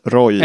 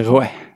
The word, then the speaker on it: Roi: Roy eller står det på R-O-I, men på då sen fornfranska så är det Roi.